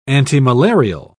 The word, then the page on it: antimalarial: British English